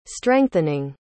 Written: strengthening

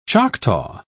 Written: choctaw

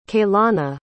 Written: kaylana

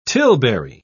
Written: tilbury

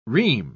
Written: ream

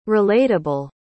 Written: relatable

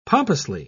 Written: pompously

